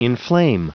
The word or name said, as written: inflame